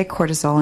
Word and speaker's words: cortisol: And I want to say cortisol, and I'm not sure if I'm, am I pronouncing that right? – I think you are.